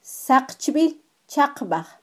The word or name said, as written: saqchbilchaqbaj